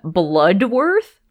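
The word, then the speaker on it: Bludworth: Tony Todd being in this movie, and his character's name is William Bloodworth, but it is spelled B-L-U-D, that, like, you say Bloodworth, but it isn't spelled Bloodworth.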